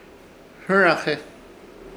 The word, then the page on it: Hɨraje: Ishir Chamacoco